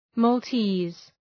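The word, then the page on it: Maltese: mɔ:lˈti:z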